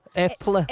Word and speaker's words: Eple: Eple.